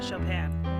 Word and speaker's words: Chopin: Chopin. Did I say that right? You did. Okay, good.